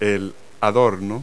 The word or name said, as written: ornament